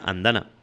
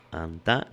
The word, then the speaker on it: Andana: O sí, porque no lleva tilde ni nada, Andana. Andana, no. Sí.